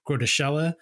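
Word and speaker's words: Groeteschele: It stars Henry Fonda as the president of the United States, Walter Matthau as the nuclear war strategist, Professor Grotoschella. Although if you look at it in text, in like the novel, it you would almost want to pronounce it grotesque.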